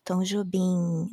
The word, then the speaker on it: Jobim: even though it ends in M, we pronounce it as an N, so it's Jacó do Bandolim, Tom Jobim, also it's like Tom Jobim, not Tom Jobim. It's not an M sound, it's an N sound.